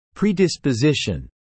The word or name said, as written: predisposition